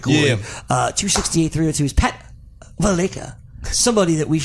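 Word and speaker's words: Valaika: It's not Valleca anymore? – No, it's Valleca.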